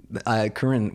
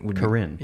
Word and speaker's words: Korine: Harmony Korine? Korine? – Korine. – Korine. – Yeah. – See, that's what I thought.